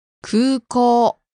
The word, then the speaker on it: 空港: 空港 Airport. – There are two long vowels in that word, so be careful. Long oo and long o sound.